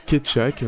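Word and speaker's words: Kecak: La séquence que tu nous proposes nous montre euh, un Kechak, hein, si je le prononce correctement, ça s'écrit K-E-C-A-K, e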